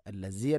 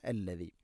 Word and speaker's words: الذي: ഹുവല്ലാഹുല്ലദി ഹുവല്ലാഹുല്ലദി അല്ലദി, ദാലിൻ്റെ ഉച്ചാരണം ശ്രദ്ധിക്കുക. അല്ലസി അല്ല അല്ലദി.